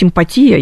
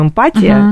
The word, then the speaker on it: эмпатия: Но на самом деле это все вообще правильно говорить эмпатия, я проверила сегодня, эмпатия, но, честно говоря, я думаю, мало кто говорит эмпатия, мы все говорим эмпатия, да, поэтому эмпатия, нужно об этом, наверное, сказать, я буду неправильно говорить, потому что я так привыкла,